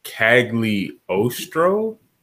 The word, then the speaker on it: Cagliostro: Lupin and the Castle of Cagliostro? – You know, yeah, that's how I would pronounce it. – Yeah, that's how I would say it. – I don't know. – It's an Italian, like, runoff, so, yeah.